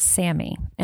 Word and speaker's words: SAMe: So the first one is pronounced SAMI, and it's spelled capital S, capital A, capital M, lowercase e.